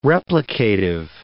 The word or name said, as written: replicative